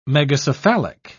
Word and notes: megacephalic: British English

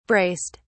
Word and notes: braced: British English

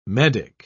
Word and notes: medick: British English

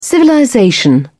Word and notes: civilization: British English

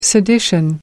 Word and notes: sedition: American English

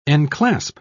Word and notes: enclasp: British English

